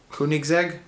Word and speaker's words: Koenigsegg: Koenigsegg, that's the car company, yeah.